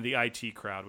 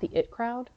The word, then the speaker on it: The IT Crowd: Oh, it's The IT Crowd, not The It Crowd? – I think it's pronounced The IT Crowd because it's about an IT department.